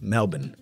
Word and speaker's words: Melbourne: The born part is something like bun, like a burger bun. So it's Melbourne.